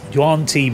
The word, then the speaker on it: Yuan-ti: So this is a Yuan Ti Malison. How you pronounce it? Yeah, Yuan Ti.